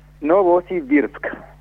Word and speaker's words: Novosibirsk: Novosibirsk.